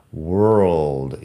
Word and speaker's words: world: fíjate que la O tampoco es como O, es E world y que la W es la misma W de wood.